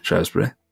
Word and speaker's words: Shrewsbury: A town called Shrewsbury, which... – Oh, it's Shrewsbury, yeah, okay. – A lot of people pronounce it Shrewsbury. – Shrewsbury. – Yeah, but it is Shrewsbury.